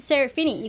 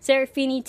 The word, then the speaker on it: Serafini: I'm your host, Marissa Serafini. You can follow me everywhere on Twitter, all those fun places, at Serafini TV. I'll spell that for those who don't know. It's S-E-R-A-F-I-N-I-T-V.